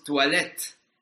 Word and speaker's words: toilette: Tenemos toilette. Toilette, volvemos a tener ahí una, vocal compuesta, que es el sonido OI que genera el UA.